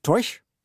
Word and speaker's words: Taoiseach: Och titeln för den irländska regeringschefen är Taoiseach, men uttalas säkert Toish. Mm. Och betyder ungefär hövding. – Jaha. – Det hör man inte så ofta på nyheterna. Irlands Toish Leo Varadkar har i dagarna besökt.